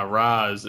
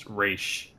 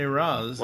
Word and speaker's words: Ra's: It's funny when throughout the episode, just some of the trivia parts, Terry, you know, saying, ah, Raz, and Talia's like, it should be pronounced Raish.